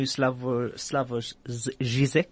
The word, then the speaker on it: Žižek: The first book I'm going to go into is the brand new Slavoj Žižek.